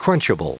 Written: crunchable

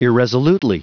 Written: irresolutely

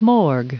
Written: morgue

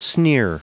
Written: sneer

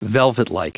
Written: velvetlike

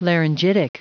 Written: laryngitic

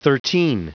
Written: thirteen

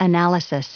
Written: analysis

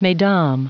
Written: mesdames

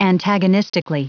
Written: antagonistically

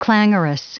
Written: clangorous

